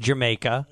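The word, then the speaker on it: D'yer Mak'er: I believe it's pronounced Jamaica. – Yes.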